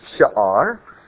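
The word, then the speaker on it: sha'ar (שאר): The Hebrew word for remnant is a three-letter word, shen, aleph, resh, and it's pronounced sha'ar, and it has a longer spelling, sharit, which is more often used, but this is the root.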